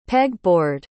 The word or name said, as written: peg-board